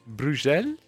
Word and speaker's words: Bruxelles: Hoe spreek je dat uit? Ja, doe het nog eens. – Bruxelles? – Prachtig dat jij dat nu even zegt.